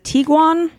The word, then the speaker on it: Tiguan: Is it Tiguan? – Yeah.